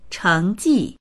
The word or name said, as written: cheng2ji4